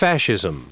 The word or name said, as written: fascism